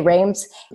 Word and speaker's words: Reims: So there were 12 of us that went to their headquarters in, it's called Reims, I think is how you pronounce it. If you're from North America, you say Reims.